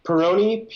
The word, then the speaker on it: Pirrone: How do you say your last name? – Peroni. – Peroni. – P-I-R-R-O-N-E.